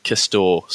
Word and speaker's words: Castore: Just quietly, I've been looking at the pronunciation and it's Castore, silent E.